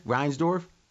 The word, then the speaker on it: Reinsdorf: I mean, if you watch The Last Dance, you couldn't have been a huge fan of what, Reinsdorf? Is that how you say it, AJ? Reinsdorf? – Yes.